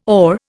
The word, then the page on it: OR: Indian